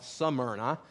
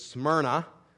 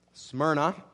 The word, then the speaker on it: Smyrna: I tend to want to move that Y forward and call it Smyrna, but it is not. It is Smyrna. Smyrna.